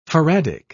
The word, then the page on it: faradic: British English